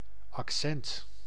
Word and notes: accent: /ʔɑk.ˈsɛnt/

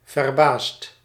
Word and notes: surprise: /syʁ.pʁiz/; France

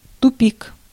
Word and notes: cul-de-sac: [ˈkʌl.də.ˌsæk]; American English

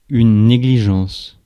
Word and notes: négligence: France; [ne.gli.ʒɑ̃s]

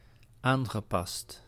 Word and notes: aangepast: /ˈaːŋɣəˌpɑst/

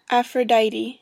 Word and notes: Aphrodite: American English; [ˌæf.ɹə.ˈdaɪ.ɾi]; /æfrəˈdaɪti/

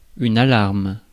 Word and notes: alarme: [a.laʁm]; France

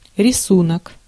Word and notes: рисунок: /rʲɪˈsunək/; risunok

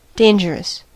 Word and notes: redoutable: IPA: /ʁə.du.tabl/; French; France

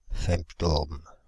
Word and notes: femto-ohms: /fɛm.tɔ.om/

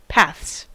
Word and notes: paths: /pɑːðz/; /pæðz/; /pæθs/

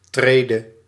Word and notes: Stufe: /ˈʃtuː.fə/

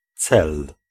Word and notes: aim: /eɪm/

